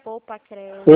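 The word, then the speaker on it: PoupaCredi: É, o Credi é com um D mudo no final, tá? Poupa Credi, tudo junto. – Peraí. Poupa, Poupa Credi. – Exatamente, Poupa Credi.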